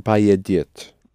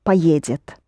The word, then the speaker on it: поедет: Let's pronounce it properly. – Он поедет. – Поедет. Accented on е, да? Он поедет.